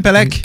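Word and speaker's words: Pelech: Eh non, c'est Adam Polish. Adam, Adam Pelek. Pelek. Ouais, c'est comme ça que ça prononce.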